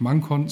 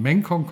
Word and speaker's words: ManCon: für die spannenden Informationen zum mengkong kongress Jetzt habe ich auch verstanden, wie er ausgesprochen wird, nicht Mangkon, sondern mengkong kongress 2020 in Reda Wiedenbrück am 3.